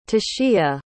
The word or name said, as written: tesia